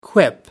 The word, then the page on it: quip: American English